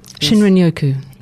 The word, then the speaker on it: Shinrin-yoku: Shinrin-yoku. It's not quite precise, but it's close enough.